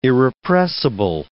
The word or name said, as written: irrepressible